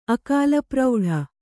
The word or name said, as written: ಅಕಾಲಪ್ರೌಢೆ